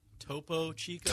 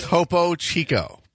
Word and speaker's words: Topo Chico: Topo Chico. – Topo Chico.